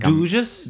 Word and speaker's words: Dugges: It's called, it's spelled D-U-G-G-E-S. I'm going to say Dugs, but I feel like Dugges? – Dugges.